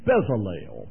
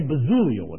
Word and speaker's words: Bezalel: I always mispronounce his name. It's Bezalel, but whatever. Say Bezaliel if you'd like, whatever the case is.